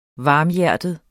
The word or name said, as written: varmhjertet